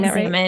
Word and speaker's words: examen: So to have like the rule of life and the examen, is that how you pronounce it? Am I saying examen. – That right? – Examen.